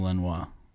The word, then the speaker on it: Lanois: that's when you get a Daniel Lanois.